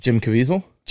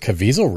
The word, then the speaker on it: Caviezel: Jason Cavicelli? – Jim Caviezel? – Is it Caviezel, really? Yeah, that's how you say it? – Jim Caviezel.